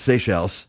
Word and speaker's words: Seychelles: I was pronouncing it Seychelles my entire life. Come to find out, it's Seychelles.